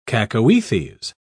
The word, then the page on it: cacoethes: British English